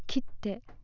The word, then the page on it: kitte: Japanese